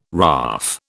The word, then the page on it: RAAF: British English